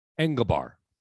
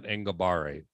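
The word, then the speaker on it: Enagbare: Engelbar, JJ Engelbar. It's not Engelbare, it's Engelbar.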